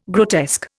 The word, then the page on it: grotesque: Indian